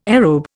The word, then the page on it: aerobe: Indian